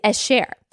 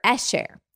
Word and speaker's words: esher: Um, another word of happiness that we see elsewhere in scripture is esher or esher. I think the emphasis is on the first part, which is according to the resource that I have, a masculine noun meaning a person's state of bliss.